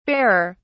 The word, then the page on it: barer: British English